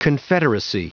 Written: confederacy

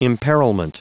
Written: imperilment

